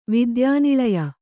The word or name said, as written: ವಿದ್ಯಾನಿಳಯ